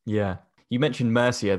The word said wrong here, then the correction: Murcia: there, and I definitely didn't say that correctly. Can you say it again for me? Because it sounded awesome when you said it. – I think it's Murcia, but they'll probably pronounce it a different way.